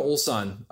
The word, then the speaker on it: Ulsan: So if you ever go to Ulsan, how do you spell that? – It's U-L-S-A-N, okay.